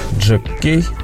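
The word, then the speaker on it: Jack K: Это французский дуэт Джек Кей, Джек К пишется.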